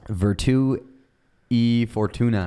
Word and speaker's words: Virtù e Fortuna: How do I say this? Virtù, Fortuna. – Yes, that is the correct pronunciation of the episode title. Will you say it normally? – Yeah, it's a Virtù in Fortnite, I believe. – Okay, what is it for real? – Virtù e Fortuna.